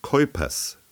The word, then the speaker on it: Kuypers: Mein Name ist Keupers. Das schreibt man Kuypers.